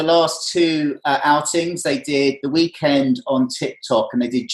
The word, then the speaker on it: The Weeknd: I'm seeing virtual concerts with Travis Scott and The Weeknd. Is that how you pronounce? He's knocked all his... – It's called The Weeknd.